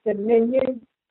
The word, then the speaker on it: simian: Recent discovery suggested the virus probably caused from chimpanzees and other non-human primates infected with the, this is um, pronounced uh, Dominion, S-I-M-I-A-N, infected